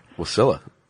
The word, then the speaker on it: Wasilla: So they used to go up to Wasilla, or Wasilla. I don't know how they pronounce it up there. – Wasilla. – Is it Wasilla? – Yeah, that's Sarah Palin's.